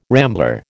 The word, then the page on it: rambler: American English